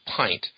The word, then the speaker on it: pint: So it turns out INT words in English are all pronounced int, lint, tint, flint, and so on, with that single exception of pint.